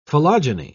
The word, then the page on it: philogyny: British English